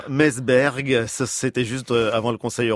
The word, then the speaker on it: Meseberg: Je ne sais pas si je le prononce bien, Natacha. – Mon avis, ça doit se prononcer Meseberg.